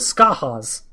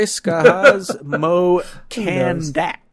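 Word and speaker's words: Skahaz mo Kandaq: The second is Skehaz, I prefer Skahad. Get it? Skahad. Hey. – I always go with Skahaz. – Okay, Skahaz. Mo Kandak.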